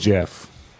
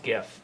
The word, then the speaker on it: Geoff: It's Jeff. Jeff. – He says it's pronounced GIF, but it's Jeff. GIF. No, it's definitely Jeff.